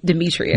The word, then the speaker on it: Demitria: Demitria.